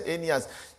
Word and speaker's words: Aeneas: Aeneas, who had been bedridden for 8 years and paralyzed. And Peter said, Aeneas, Jesus Christ heals you.